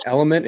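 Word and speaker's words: LMNT: Yeah, I think Liquid IV and Noon tablets, N-U-U-N, those are my two top. – I have these right here. These are, I think it's pronounced Element. It's L-M-N-T.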